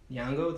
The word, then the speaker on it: Nyong'o: Nyong'o.